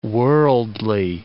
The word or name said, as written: worldly